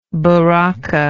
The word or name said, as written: baraka